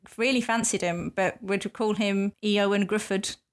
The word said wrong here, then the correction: Griffiths: Uh, oh, is that not how you say it? no, no, it's not. huh. – I mean, he's Griffiths.